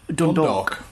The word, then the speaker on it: Dundalk: You can probably help me with the pronunciation here. I'm bad at pronunciation as it is, but Dundalk? Are we saying that right? – Dundalk. – Dundalk. There we go.